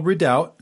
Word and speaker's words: redoubt: And I'll spell redoubt because it has a silent B in it. It's R-E-D-O-U-B-T, redoubt.